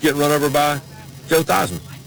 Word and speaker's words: Theismann: You pronounce his name is Theismann, but they wanted to do a Heisman campaign for him at Notre Dame, and he became Theismann.